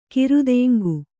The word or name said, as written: ಕಿರುದೆಂಗು